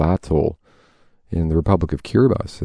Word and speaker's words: Kiribati: Hey, by the way, it's spelled Kiribati. We pronounce it Kiribati.